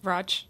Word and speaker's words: Radch: how do you say the language? Radch? Roch. Roch. Yeah.